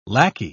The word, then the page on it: lacquey: British English